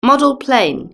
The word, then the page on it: model plane: British English